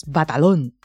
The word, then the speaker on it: Batalon: Jacob Batalon. But actually, it's Batalon.